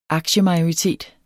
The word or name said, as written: aktiemajoritet